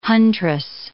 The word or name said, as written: huntress